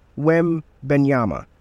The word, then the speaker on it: Wembanyama: And I just want to say, Victor Wembanyama, if I'm pronouncing that right, I believe I am. Let me see. Oh, yeah. Victor Wembanyama. All right. So I think I did pronounce it well.